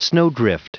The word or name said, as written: snowdrift